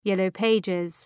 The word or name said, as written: Yellow Pages